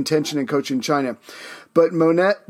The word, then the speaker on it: Monet: There's a famous painter, M O N E T.